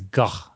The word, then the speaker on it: Gogh: But the end, if you don't shed a tear when Bill Nighy wonderfully explains how much Vincent van Gogh, and that's how you say it, I think it's Gogh, that's how you say it, how he explains the love he has for Van Gogh's paintings.